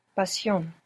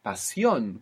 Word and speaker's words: pasión: Pasión. – Pasión. Good. And you pronounce the S because we don't write sh. Preparation. Now, if it's shun, T-I-O-N, if we have shun, it's going to be C-I-O-N in Spanish.